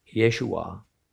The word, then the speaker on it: Yeshua: I want to begin by saying that the name Yeshua, really better way to pronounce it, Yeshua, was in Christ's day actually among the most popular of all male names given.